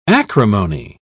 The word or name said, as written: acrimony